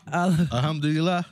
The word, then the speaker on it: Alhamdulillah: Alhamdulillah.